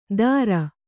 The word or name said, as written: ದಾರ